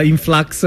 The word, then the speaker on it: In Flux: Oh, la pronuncia è giusta, In Flux? – Sì, va bene. Fai conto, per la pronuncia italiana influx è meglio, viene più riconosciuto. Okay. Però bene influx.